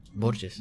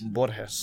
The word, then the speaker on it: Borges: And then to, you know, honor my father and his family and my ancestors before me, I decided to pronounce it the correct way. – Borges. – Borges. – Borges, excuse me. Yeah. Borges. Yeah. Borges.